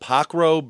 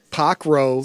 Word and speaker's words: Pokro: It is called Pockrell Brewing. – Pockrell. – Spell it, please. – P-O-K-R-O.